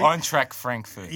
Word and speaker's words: Eintracht Frankfurt: Eintracht Frankfurt.